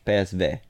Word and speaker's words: PSV: Yes, that's how you pronounce it in Dutch. PSV.